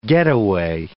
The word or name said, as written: getaway